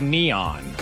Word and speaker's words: N3on: I think that you don't know because his name is Neon. – It's spelled N3on. N3on. – Yeah, but he goes by Neon.